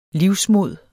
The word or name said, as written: livsmod